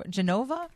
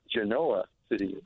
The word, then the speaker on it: Genoa City: It's not Genoa, Genova? – Genova. – Genova City. – Oh. – Hey, it's pronounced Genoa City.